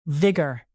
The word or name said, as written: vigor